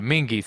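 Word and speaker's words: Menghi: Mark Mingjay, I'm saying that wrong, Marco Scotta, of course, yeah, Mingy, thank you, Jordan Ruse, of course, Snake Szabo, Alex Skolnick, and of course, John Five.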